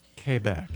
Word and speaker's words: Quebec: Quebec. – Quebec. That's how they say it in Canada.